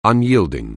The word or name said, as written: unyielding